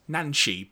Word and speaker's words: Nancy: Referred to in the script simply as Junkion Lady, she went by the name Nancy in multiple Japanese guidebooks, with the name being pronounced Nanshi, because that's how it would be pronounced, suggesting that she received the name in the Japanese 2010 dub of this episode.